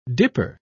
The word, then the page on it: dipper: British English